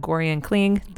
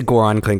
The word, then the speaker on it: Goran Kling: Sorry, dude, but Gorian Kling, that is not your correct name. – We'll just say Goran Kling because that's what he told me how to say it.